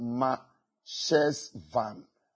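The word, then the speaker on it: Marcheshvan: It is pronounced Ma Shesvan. Ma Shesvan.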